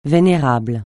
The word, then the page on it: vénérable: francais